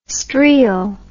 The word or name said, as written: streel